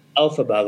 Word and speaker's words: Elphaba: You know, it's like Elphaba, like stress on the first syllable, like Dorothy. And for that matter, like Margaret and Hamilton.